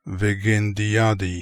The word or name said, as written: Veggendiadi